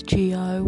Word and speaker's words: Gio: My name, you know, it can, you know, G or the name I'm trying to go for, is Gio, which is now my name on this app. Just to spell that, G-I-O.